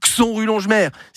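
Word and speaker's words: Xonrupt-Longemer: Ça s'écrit J-O-R-X-E-Y. Attention sur le GPS. Hein. Si vous avez une ville vosgienne qui commence par un X, ça se prononce K2S. Xon rue Longemer.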